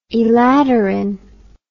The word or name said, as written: elaterin